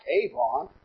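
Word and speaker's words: avon (עָוֺן): It is translated in the Old Testament from the Hebrew word avon, A-W-O-N. There are a few times that this word is spelled A-V-O-N. I kind of like Avon. I don't know if I like that spelling of the word. A-W-O-N, avon, is this Hebrew word.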